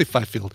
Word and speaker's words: Fifield: Let's say Fifield. – Sure. – F-I-F-I-E-L-D. It's like field with F-I at the beginning.